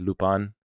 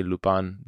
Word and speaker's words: Lupin: Now, the proper pronunciation of Lupin is Lupin, but I find that to say Lupin the entire series is going to be a little bit...